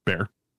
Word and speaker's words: Bexar: Oh no, bear. Hmm? It's pronounced bear. – Is it? – Yeah, I know. Yeah, I know. There's an X in there, but it's bear.